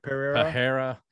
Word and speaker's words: Pereira: Pereira versus Sean Strickland.